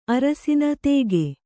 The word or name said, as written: ತೆಗೆ